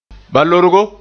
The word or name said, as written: balorgo